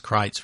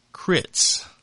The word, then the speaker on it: Critz: Too many people call it fries because it's spelled F-R-I-E-S. And then he wrote out C-R-I-T-Z, Virginia. And I said, "Well, that's Crites, Virginia." He said, "Good." Last person said, "Crits."